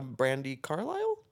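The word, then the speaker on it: Brandi Carlile: It's like meeting a Brandy Carlisle? – Yeah. – Did I say her name right? – Yeah, yeah. Okay.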